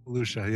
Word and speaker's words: Volusia: Did I say that right? I think wouldn't it be Volusia? Was it Volusia? – Volusia, yeah.